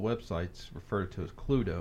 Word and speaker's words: Cluedo: In England, where musician Anthony Pratt invented the game in 1944, it's called Cluedo, pronounced Cluedo, meaning clue, and the Latin term ludo, meaning play.